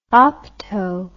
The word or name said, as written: opto